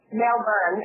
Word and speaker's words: Melbourne: Well, Melbourne is how they say it. Their R's are kind of soft.